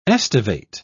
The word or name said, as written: estivate